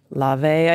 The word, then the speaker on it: Laufey: Whose name I'm going to mispronounce because it's spelled like Laufey. I think it's L-A-U-F-E-Y, but it's actually pronounced like Lave, I think, something akin to that.